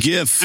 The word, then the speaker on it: GIF: Graphics, hard G. – Yeah. It's I'm Mike. – GIF.